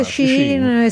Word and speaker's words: Shein: si dice Shein, si scrive Shein,